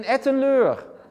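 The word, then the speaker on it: Etten-Leur: Ellenleur. – In Ettenleur.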